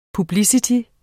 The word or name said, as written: publicity